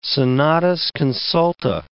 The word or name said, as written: senatus consulta